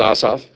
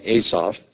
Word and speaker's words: Asaph: The correct pronunciation is Asaph, but you might call him Asaph.